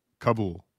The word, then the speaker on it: Kabul: Kabul.